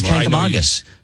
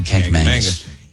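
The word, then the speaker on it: Kancamagus: It is Kankamagus. And I struggle because my whole life I said Kankamagus. And God forbid I say it now, because, oh, my God, I get harangued.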